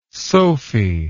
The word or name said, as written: sophie